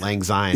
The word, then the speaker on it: Lang Syne: The new track, well, there's Auld Lang Syne. Am I saying right. – that? Lang Syne.